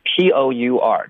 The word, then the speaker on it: pour: OK，他们的拼写有点不一样，实际上他们的发音是一模一样的，P O U R 就是泼水，我们就叫 pour。